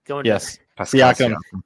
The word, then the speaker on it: Siakam: Siakam.